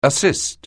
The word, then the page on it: assist: anglais